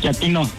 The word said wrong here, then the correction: chatino: No, chatino. – Es como chato, ¿verdad? Chatino. – Sí, la primera como chato, chatino.